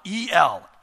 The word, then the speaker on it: El: But the particular word that Isaiah uses here in chapter 9, verse 6, is the Hebrew word El. You spell it E-L, El.